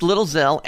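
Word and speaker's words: Lil Xxel: It's featuring Lil Zell, which is spelled XXEL. Am I right on the spelling? – Yes, sir. – XXEL, but it's pronounced Lil Zell and Chance the Rapper.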